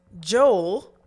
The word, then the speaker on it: Joel: Yeah, well, so Joel Osteen. – Oh, him. – What's crazy is that Americans pronounce J-O-E-L as Joel, and in Trinidad it's Joel.